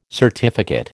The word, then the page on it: certificate: American English